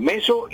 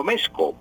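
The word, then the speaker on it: mezo: Y no, perdón, meso y no mezco. Mezco es incorrecto, es un barbarismo decir mezco. Se dice meso, pues se escribe meso con Z, pero cuando ya se hace la conjugación del verbo, entonces cuando se dice tú meses, él mece, esa Z se cambia por C, entonces queda con C.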